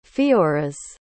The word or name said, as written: feoras